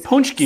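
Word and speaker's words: pączki: Punchki. So, he says it's pronounced punchki.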